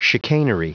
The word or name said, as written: chicanery